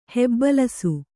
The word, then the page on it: ಹೆಬ್ಬಲಸು: hebbalasu